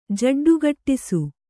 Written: ಜಡ್ಡುಗಟ್ಟಿಸು

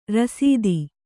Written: ರಾಸ್ತಿ

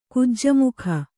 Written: ಕುಜ್ಜಮುಖ

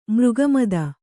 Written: ಮೃಗಮದ

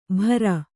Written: ಭರ

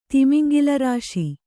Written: ತಿಮಿಂಗಿಲರಾಶಿ

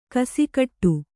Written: ಗಸಿಗಟ್ಟು

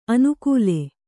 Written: ಅನುಕೂಲೆ

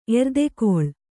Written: ಎರ್ದೆಗೊಳ್

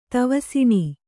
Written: ತವಸಿಣೀ

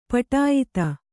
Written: ಪಟಾಯಿತ

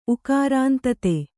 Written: ಉಕಾರಾಂತತೆ

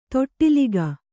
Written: ತೊಟ್ಟಿಲಿಕ್ಕು

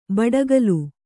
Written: ಬಡಕಲು

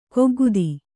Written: ಕೈಕಾಗದ

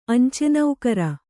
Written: ಅಂಚೆನೌಕರ

